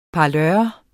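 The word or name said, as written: parlør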